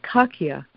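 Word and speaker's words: koekje: and it's called kakia. – Oh, it's spelled very differently from how it sounds.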